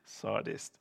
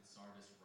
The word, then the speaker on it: Sardis: Sardis.